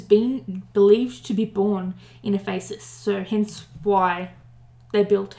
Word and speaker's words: Ephesus: It's Ephesus. I would have thought it was Ephesus, but I'm wrong. It's Ephesus. which is now in the modern town of Selçuk in present-day Turkey.